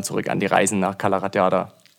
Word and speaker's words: Cala Ratllada: Es müsste Cala Ratllada sein.